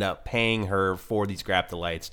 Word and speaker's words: graptolites: What's right? Is it Graploidites or... – Graptolites. I'm sorry. – Graptolites. – Yeah, Graptolites. – It's Graptolites? Okay. Yeah.